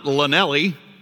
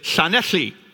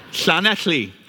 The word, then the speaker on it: Llanelli: And so the name of this town is not Llanelli, as many Americans or people from around the world would pronounce it. It's Llanelli. Llanelli.